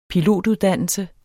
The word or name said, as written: pilotuddannelse